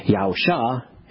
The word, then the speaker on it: Yahusha: But his primary mission is to save, which is why Yahusha is the preferred spelling and pronunciation.